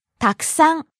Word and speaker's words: たくさん: たくさん many, a lot, much. – たくさん、たくさん。 – Starting low, rising on the second syllable ku, staying high.